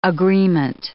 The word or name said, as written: agreement